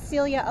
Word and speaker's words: Celia: So my name is Celia Alario, that's C-E-L-I-A. My last name is spelled A-L-A-R-I-O.